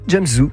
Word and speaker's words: James Zhu: je ne sais pas si on prononce bien. – C'est ça, James Zhu, oui, exactement.